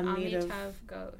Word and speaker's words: Amitav Ghosh: Where did you find that Amitav Ghosh? I'm probably pronouncing this man's name horribly wrong. – Oh, yeah. Amitav Ghosh.